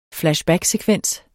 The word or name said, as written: flashbacksekvens